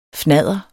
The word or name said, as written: fnadder